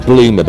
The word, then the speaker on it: Blumer: My surname's Bloomer, but it's spelled B-L-U-M-E-R rather than the 00 option.